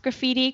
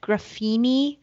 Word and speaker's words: graffiti: And you also, the best part was that Martha didn't know how to pronounce graffiti correctly. She said it graffini with an N.